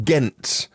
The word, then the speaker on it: Ghent: The XC40 Recharge began in Ghent with a silent H in Belgium.